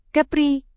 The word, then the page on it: kepri: indonesian